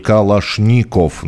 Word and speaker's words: Калашников: У Горана Бреговича, ну, во-первых, хит даже не Белочал, а Калашников, или как они поют, Калашников, на, на букву «и» у них там ударение.